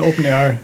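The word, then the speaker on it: OpenAIRE: Das ist A-I-R-E. – Ja, aber das, ja, das, genau. Das spricht man immer noch R aus. – Das spricht man immer R aus. Okay. Aber gut. Das heißt dann Open Air.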